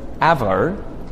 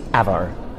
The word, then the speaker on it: avo: Whereas avo, it's a short vowel sound. It's a slightly different one, like words like hat or had or map, but it's very short. It's avo, like avocado, as opposed to if I said R at the start, it would sound like avocado instead of avocado.